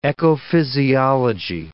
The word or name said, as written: ecophysiology